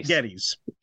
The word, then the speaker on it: Geddes: Geddes.